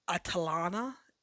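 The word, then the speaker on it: Atalanta: First one up is At- Atalana, I believe is how they pronounce it, to me, it looks a little bit like Atlanta, but it's not actually it pronounced that way.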